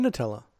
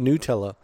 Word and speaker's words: Nutella: I don't believe there's any cocoa in Nutella. – There has to be. – Though also, supposedly, it's actually pronounced Nutella.